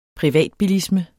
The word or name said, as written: privatbilisme